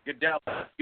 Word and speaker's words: Gadelha: She defeated Claudia Gadelha. – Okay, that's kind of the way I thought it was pronounced.